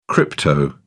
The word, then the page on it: crypto: British English